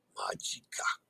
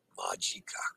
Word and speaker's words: Magica: Remember to use the open ah, as in father, when you letter A pronunciations. Magica. Magica.